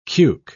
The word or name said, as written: cuke